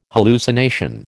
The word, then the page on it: hallucination: American English